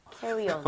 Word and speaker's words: carry-on: Carry on. – 물어보시는 거예요? – 예. – Okay. Carry on back. 맞습니다. 자, 이것도 여러분 시간이 걸려요. 이게 암기가 되려면 캐리어가 아니라 실제로는 carry on.